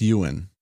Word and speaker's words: yuan: Yuan! – I think it's Ewan, like Ewan McGregor.